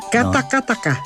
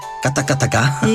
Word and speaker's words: Katakataka: Vamos talvez escutar aqui uma versão de Katakata. – Sim, Katakataka. – Katakataka. – Isso. – Falta uma sílaba.